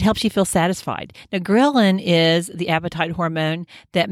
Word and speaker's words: ghrelin: You've probably seen the word leptin, a very straightforward word, L-E-P-T-I-N. And then, but ghrelin is such a tough word to pronounce. It's G-H-R-E-L-I-N, but it's pronounced ghrelin, like you're grilling on the barbecue.